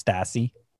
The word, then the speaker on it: Stassi: S-T-A-S-S-I. You decide for yourself how to say that. Phonetically, it looks like Stassi, but whatever.